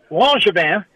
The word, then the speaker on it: Langevin: I said, down here in South Louisiana, where we got a lot of French people, we would call that Langevin. And he looked at me and he said, well, actually, that's how you say it. So the answer to your question is Langevin.